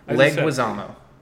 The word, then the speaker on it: Leguizamo: Try it again. – Leguizamo. – Wow, I think you did it right that time. – Yeah, see, before I was saying Leguizamo, which is probably is not correct.